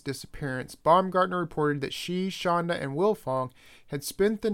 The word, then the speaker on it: Schanda: Baumgartner is the romantic partner of Wilfong's uncle, Lawrence Philip Shanda, that's spelled S-C-H-A-N-D-A.